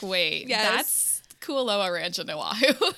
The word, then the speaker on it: Kualoa: It's Kualoa.